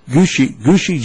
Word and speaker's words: Gucci: And then there's G-U, I think it's pronounced Gucci, G-U-C-C-I, Gucci, good or cool.